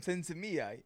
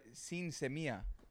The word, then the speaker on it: sin semilla: No, in Spanish, that's what it's I was aiming sin semilla. Seedless.